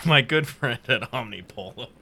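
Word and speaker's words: Omnipollo: Collaboration with Omnipolo? Am I saying that right? – Yeah. You're right.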